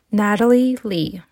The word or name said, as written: natalie ly